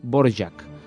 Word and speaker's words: Dvořák: És el cas de Dvořák, que sabreu que s'ha de pronunciar com Antonín Dvořák.